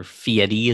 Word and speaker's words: Fieri: Now, like most normal people, I don't usually watch those, but it had Guy Fieri in it, or Fieri as he chooses to pronounce it.